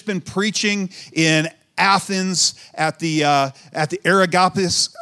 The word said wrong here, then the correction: Areopagus: the Areopagus, I think that's how you say it.